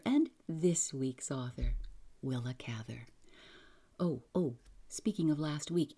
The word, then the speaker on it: Cather: Yes, I've heard all the pronunciations. I'm going to try to stick with Cather.